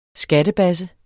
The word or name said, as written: skattebasse